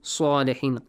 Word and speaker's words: salihin: Make an aw sound, pronounce it with an aw sound. Qawman salihin. Sa, not sa, sa.